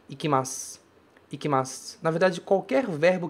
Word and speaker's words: ikimasu: Ikimasu. Esse u não é pronunciado como u. Ele some, ele deixa lá, fica só, assim, sutilmente a ideia de que um dia ele esteve ali. Ele é escrito, mas a gente não pronuncia.